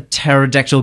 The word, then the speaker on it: pterodactyl: Why can't you hear a pterodactyl go to the toilet? – I don't know. – It's very cerebral because the P is silent.